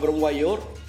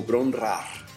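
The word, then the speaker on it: Bron-Yr-Aur: Y según yo lo pronunciaba Bron Wayor, pues lo pronunciaba mal, porque al parecer, según lo que encontré, se pronuncia algo así como Bron Rar, ¿no?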